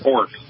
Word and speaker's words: Horch: Well, there's a beautiful Hork, which is H-O-R-C-H, but it's pronounced Hork, which is the grandfather of Audi, actually.